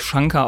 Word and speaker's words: Shankar: Aber nee, also es kommt ja eigentlich aus dem Indischen es wird eigentlich Shankar ausgesprochen.